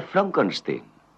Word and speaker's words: Frankenstein: Frankenstein. ¿Me toma el pelo? No, se pronuncia Frankenstein.